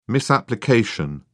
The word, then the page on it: misapplication: British English